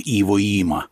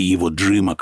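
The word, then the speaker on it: Иводзима: А вот что-то я не припомню, чтобы, скажем, где-нибудь на Клязьме была бы площадь Эль-Аламейн или даже в Москве какой-нибудь переулок Иво-Има или Иво-Джима, как его произносят.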